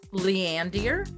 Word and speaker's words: Leander: Our top cities this week are Lindier. Is that how you say it? Landier? – Maybe Leander? – Leander. – Leander. – There's Leander. Leander, Texas.